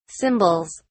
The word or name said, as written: symbols